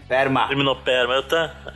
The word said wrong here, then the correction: Limnoperna fortunei: É então é, é com N. Errou. Errou feio, errou feio, errou rude. – Liminoperna Fortunei.